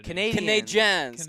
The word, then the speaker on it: Canadiens: Canadiens. It's an E, sports fans. It's a French-Canadian franchise.